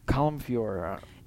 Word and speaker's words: Colm Feore: Colm Feore.